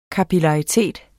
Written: kapillaritet

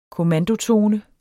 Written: kommandotone

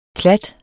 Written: klat

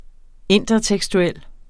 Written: intertekstuel

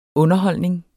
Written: underholdning

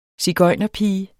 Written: sigøjnerpige

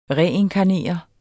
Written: reinkarnere

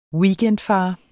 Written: weekendfar

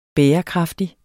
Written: bærekraftig

